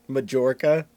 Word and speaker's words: Mallorca: Mallorca? Even mm-hmm. I know you don't pronounce the J in Mallorca.